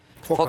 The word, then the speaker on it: Phokas: Milattan sonra 1000 civarında zamanın Bizans imparatoru Phokas mı diye okunuyor? – Fokas, Phokas. – evet.